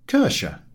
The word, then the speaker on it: Kirche: Kirche.